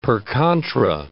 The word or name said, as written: per contra